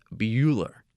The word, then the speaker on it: Buhler: Yes, Bueller, but it's spelled differently. B-U-H-L-E-R.